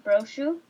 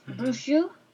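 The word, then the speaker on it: Bruxo: It's called Castelo Brechu. – Brochu. Yeah. – Brochu. Sorry, I can't say that.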